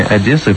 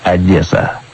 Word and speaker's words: Одесса: После этого разговора заглянул я в британскую энциклопедию и выяснил, что название «Одесса» появилось в 1795 году, когда порт Хаджибей был переименован в честь древнегреческого поселения «Одессос». И уж это-то название я буду произносить с оборотным «э».